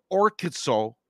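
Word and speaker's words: Orkizo (ὁρκίζω): The demoniac recognizes Jesus and uses that same root word, orkizo, right? Is that how you say it? Orkiko? – Yeah. Orkizo, yes.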